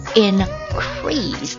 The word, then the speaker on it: increase: 那它名词的时候念作 increase，动词的时候念作 increase。